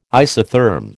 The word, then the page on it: isotherm: American English